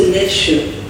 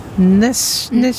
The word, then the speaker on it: Nässjö: Nu, har jag fått svaret på hur Trafikverket uttalar stationen Nässjö på svengelska. Lyssna noga nu. – Mm. – The 626 train to Nässjö, Alvesten, Växjö will depart from track 2B. – Nässjö.